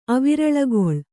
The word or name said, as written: ಅವಿರಳಗೊಳ್